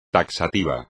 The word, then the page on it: taxativo: espagnol